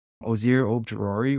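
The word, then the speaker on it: Azeez Ojulari: Ozir Ojulari, which is really hard to say. I'm shocked I said that right.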